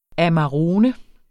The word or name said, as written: amarone